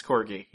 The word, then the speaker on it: Kordey: Uh, there's art Frank Quitely, but also, uh, Ethan Van Sciver and Igor, how do you say that guy's name? Igor Kordey? Cord, I'm guessing? That's me. – Strong, uh, Corgi? – Yeah, it's Corgi.